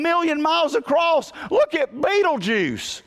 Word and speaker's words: Betelgeuse: How do you pronounce Betelgeuse? Betelgeuse. Betelgeuse. You know, you can look that up and it says it like 14 times.